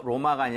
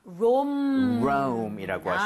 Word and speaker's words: Rome: R-O-M-A가 아니라 영어, 그죠? 영어식 표기는 R-O-M-E고 발음도 심지어 로마가 아니라 Rome이라고 하시면 되겠습니다.